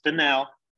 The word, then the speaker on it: Fennell: I just learned from you the pronunciation of your last name is Fennell, and you are an artist, and I am fascinated by art and love art.